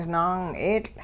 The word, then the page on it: naang'eetL': Hupa